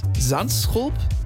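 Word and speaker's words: Zandschulp: Und Sandschulp, Sand, mit Z ist Sand.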